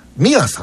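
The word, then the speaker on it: 心愛: たくさん届いていると思いますが、千葉県野田市の野田市立の小学 4 年生、栗原美也さんって読むのね。これ心に愛と書いて、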